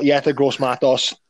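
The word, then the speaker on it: Yetur Gross-Matos: I've got Yetur Gross Matos. That's how I say his name.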